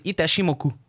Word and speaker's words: Itachimoku: Alors le morceau s'appelle Battle Turtle et le nom du groupe c'est Itachimoku. C'est comme ça que le nom du groupe se prononce.